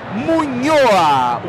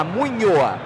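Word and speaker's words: Muñoa: Munhoa. O N tem o tremazinha, é como o NH, Munhoa.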